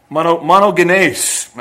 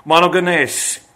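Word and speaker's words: Monogenes (μονογενής): Monogenes. Now listen to that online. That's how it's pronounced online, so I'm going to go with it. Monogenes.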